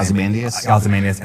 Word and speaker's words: Ozymandias: Ozymandias, and then a new Rorschach.